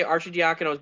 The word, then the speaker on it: Arcidiacono: is that how you say it? I thought it was Arcidiacono. Oh, wait, no, not Ar- uh, yeah, it's, Ar- it's Arcidiacono.